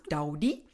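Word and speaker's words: Daði: Well, I actually looked up the pronunciation of his name, at least, because it's like D-A-O with a thing on top of it, I. So that's Daudi.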